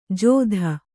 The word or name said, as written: ಜೋದ